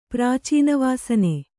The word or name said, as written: ಪ್ರಾಚೀನವಾಸನೆ